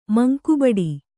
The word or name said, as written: ಮಂಕುಬಡಿ